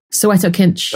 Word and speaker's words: Soweto Kinch: Soweto Kinch.